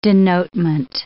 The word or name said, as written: denotement